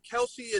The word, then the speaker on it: Kelce: I got Kelce and then Kels. My real name is Kels, so I mean, I just kind of roll with the punches.